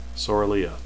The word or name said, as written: sorilea